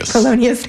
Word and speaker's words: Polonius: Now, so your character, say his name again because I said it wrong before. – Polonius, thank you.